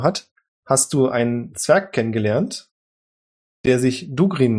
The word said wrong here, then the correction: Durgin: nennt oder Durgin, Entschuldigung bitte, ich habe es falsch ausgesprochen, Durgin Goldbart.